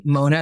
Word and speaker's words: Mona: It's like I have this dilemma living here in this part of the world because my name, Mona, is spelled with an O, and it's one of my biggest pet peeves because in Arabic, there's Mona, but in the Arab world, it's M-U-N-A, so it's Muna.